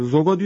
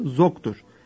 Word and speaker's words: Zog: Yanlış olarak Kral Zogo diyoruz. Adı Zog'dur. Arnavutça söylenişte Zogo Zog diye okunuyor.